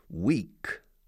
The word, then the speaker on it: weak: Weak. Weak. Es pronunciado como semana, pero escrito weak.